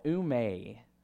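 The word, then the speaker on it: oume (οὐ μή): It's the Greek word for, it's called oume. Now, the phonetic spelling is oume. So that is how you pronounce it right there.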